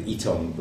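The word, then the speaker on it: Ytong: We chose the Yitong block. That's Y-T-O-N-G. Slightly difficult for some people to pronounce it that way, but from the German Yitong works. – Right. – A lot of New Zealanders will say Yitong.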